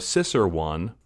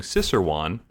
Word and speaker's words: Cicerone: And now we're lucky because we have a Cicer one here, studying Cicer one. Is that how you say it out loud? I've only read it before. – It's Cicerone.